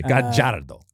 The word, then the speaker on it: Gallardo: I always forget this, but in Argentina, the two L's are pronounced like a J. – Sorry, excuse me. Gallardo.